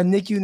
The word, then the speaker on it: NICU: Yeah, a NICU nurse yeah.